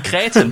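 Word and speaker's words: kretin: En kreten? Er det ikke sådan, man udtaler på dansk? – jo, jo, det siger en kreten.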